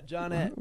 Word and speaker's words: Johnette: Yeah, Jonette. – What? – Jonette. – How do you spell that? – J-O-H-N-E-T-T-E. – J-O-H-N-E-T-T-E. Jonette.